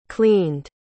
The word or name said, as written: cleaned